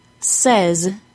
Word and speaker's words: says: Now, one more time to make sure you are using a different vowel sound in the words say and saying than you are using in says, and said.